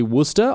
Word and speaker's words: Worcestershire: Like, Worcestershire is apparently Worcester.